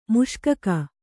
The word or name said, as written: ಮುಷ್ಕಕ